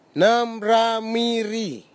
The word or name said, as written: nimramiri